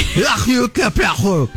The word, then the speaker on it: Hercule Poirot: Well, there's a few, but the big one is Hercule Poirot. – I'm sorry? Excuse me? – Well, wow. I had something bless stuck you in my throat. I'm sorry. Hercule Poirot.